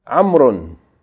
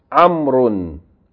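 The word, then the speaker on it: Amrun (عمرو): The proper noun, Amrun, is written with a waw, which is not pronounced. The waw is not pronounced. It's written, but it's not pronounced. Amrun, Amrun.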